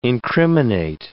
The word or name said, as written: incriminate